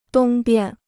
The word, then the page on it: 东边: dōng bian